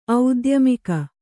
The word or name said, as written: ಔದ್ಯಮಿಕ